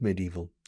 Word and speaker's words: medieval: fijaos en la pronunciación de medieval, medieval, se escribe medieval, the spelling is identical in English as in Spanish. The pronunciation is medieval.